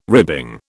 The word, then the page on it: ribbing: British English